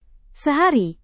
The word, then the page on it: sehari: indonesian